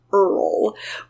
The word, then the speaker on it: Eorl: Well, the Encyclopedia of Arda claims this guy's name should be pronounced Earl, but I'm not going to do that because it makes him sound like an electrician or something.